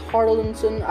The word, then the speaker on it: Harrelson: I'm sorry, the last time I talked about this movie, I said Hardinson or something like that, or I don't know, Harrison, I think, but I'm really sorry about that. It's Harrelson.